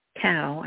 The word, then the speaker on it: Cao: H-U-N-G, Cao, I guess that's how you pronounce it, C-A-O, and he's a former Navy Special Operations and a candidate for U.S.